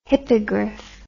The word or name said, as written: hippogryph